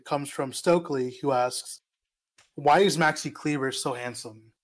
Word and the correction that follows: Kleber: Why did you say it like that? – Sorry. Maxi Kleber. – Thank you.